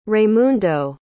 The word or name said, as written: Raymundo